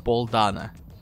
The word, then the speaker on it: Дано: Это Пол Дано? Вообще он Пол Дана. – Пол Дана. Я, я всегда произносил его как Пол Дано, и я был неправ.